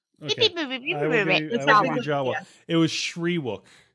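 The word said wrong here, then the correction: Shyriiwook: It's Sherwook. I'm being corrected by my fiance. Oh, it's pronounced Sherwook.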